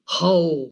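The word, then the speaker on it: hao (好): Egy másolhangzóval és egy diftangussal próbálom kiejteni, hao, ez kínaiul a jó.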